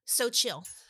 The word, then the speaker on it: Xochitl: So Chill.